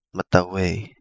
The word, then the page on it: Matawhei: Kapingamarangi